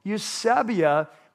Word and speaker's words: Eusebia: So the word in Greek is the word Eusebia, and it's spelled like this, E-U-S-E-B-I-A, Eusebia.